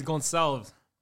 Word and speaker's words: Gonçalves: Yeah, that's Pedro con calves, man. Pedro con calves. – Con calves. – Isn't that con calves? – That's Gonçalves.